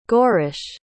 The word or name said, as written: goarish